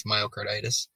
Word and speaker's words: myocarditis: Myocarditis? – Yes.